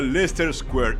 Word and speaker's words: Leicester: A plena luz del día, en medio de Leicester Square. Había fotógrafos y lo sabías. – Escúchame, se pronuncia Leicester Square.